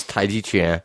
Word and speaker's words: Tai Chi Chuan: Just for the record, it's Tai Chi Chuan. Right, it's J-I. The C-H-I has nothing to do with chi, mystical energy, or whatever energy. – That's the big thing. I mean, I mispronounce chuan and chin all the time, but it's G and not chi.